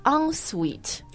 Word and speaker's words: ensuite: L-O-O. – L-O-O. – Gotta use the loo. – Gotta use the loo. – 但我自己搬到加拿大来哦，我就发现特别你要买房子、租房子，人家说这个房子有几个厕所什么，他经常会用到有一个词 ,en-suite. – Oh, yeah. – Right, E-N-S-U-I-T-E.